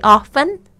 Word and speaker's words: often: 或者是你可以说 Do you go to the movie very often? 都可以，这个 often 都有人在用，这个发音的差别，虽然它有那个 T，但是那 T 常常是就是 silent，就是不发音的。